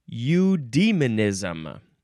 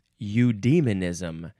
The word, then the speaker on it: Eudaimonism: Eudaimonism. Eudaimonism. E-U-D-A-E-M-O-N-I-S-M. And you can also spell it E-U-D-A-I for some reason.